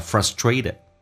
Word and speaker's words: frustrated: 那么 being frustrated 这个单词美式发音或许有点难听懂， frustrated 发着准，后面的 t 又发成 d 叫 frustrated。